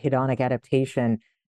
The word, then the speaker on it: hedonic adaptation: You know, tell us about hedonic adaption. Did I pronounce that correct? – Yeah. So basically, psychologists often will talk about hedonic adaptation.